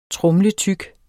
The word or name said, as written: tromletyk